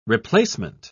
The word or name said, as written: replacement